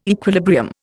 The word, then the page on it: equilibrium: Indian